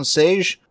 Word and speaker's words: Sejic: It's Stepan Sage. It's spelled S-E-J-I-C, but he's Croatian.